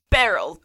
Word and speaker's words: Beryl: Beryl, B-E-R-Y-L. – Beryl. Beryl, like... Beryl. – Maybe I'm saying it wrong. I was like, this is spelled like Cheryl, except Beryl.